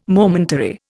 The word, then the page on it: momentary: Indian